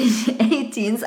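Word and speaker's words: A*Teens: Weil das hieß nämlich 18s ausgesprochen tatsächlich.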